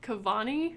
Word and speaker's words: Cavani: And it's who knows if I'm saying this right, Cavani. – That's correct. And Silva.